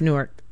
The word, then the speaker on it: Newark: I know they are different pronunciations. – Yes, it's Newark.